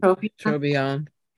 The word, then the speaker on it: Trobriand: I don't know how to pronounce that. – Yeah, I think Trobriand.